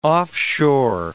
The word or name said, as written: offshore